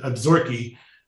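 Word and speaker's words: Absaroka: and beautiful mountain scenery in what I think are called the Absorki. It's spelled Absorca, but it's pronounced a little differently.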